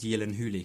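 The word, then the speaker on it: Gyllen: He tells me it's actually pronounced Yillen Yes, so, yes, yeah.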